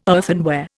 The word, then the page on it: earthenware: Indian